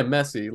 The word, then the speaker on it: Messi: Yeah, Messi, Lionel Messi.